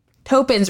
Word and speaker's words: Toppan: Toppan's right. – Toppan.